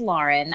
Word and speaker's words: Lauren: You are so nice to ask. I truly don't care. Everybody says it. My husband says it wrong. but it is Lauren.